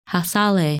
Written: Hassaleh

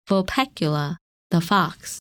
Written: Vulpecula

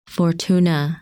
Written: Fortuna

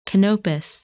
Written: Canopus

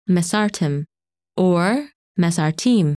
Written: Mesarthim

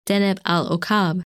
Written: Deneb el Okab

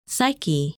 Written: Psyche

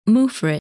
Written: Muphrid

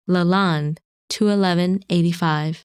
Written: Lalande 21185

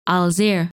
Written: Alzirr

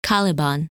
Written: Caliban

